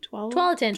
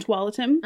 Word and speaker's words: Tualatin: I was like, oh, how do I pronounce this? Tualatin? – Tualatin. – Tualatin.